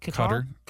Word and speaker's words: Qatar: Or and Qatar or however you pronounce it. – Qatar.